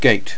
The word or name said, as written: gate